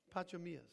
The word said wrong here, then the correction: Pachomius: I probably pronounced it wrong. Sorry, Pacho. Pacho. I think, you know what? I think it's Pachomius. I think it's Pachomius. You know, I was trying to pronounce, how would I say this word? Pachomius.